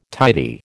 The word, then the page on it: tidy: American English